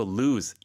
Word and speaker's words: lose: 잃어버리다는 lose. – Lose. – Z 발음이죠.